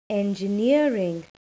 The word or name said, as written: engineering